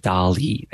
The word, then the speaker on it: DALL-E: Everything back from DALL-E, I think is how you pronounce it.